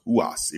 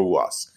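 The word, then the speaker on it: Ruas: I think it's pronounced Huas, even though it's R-U-A-S, so Arturo Huas.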